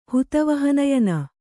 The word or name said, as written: ಹುತವಹನಯನ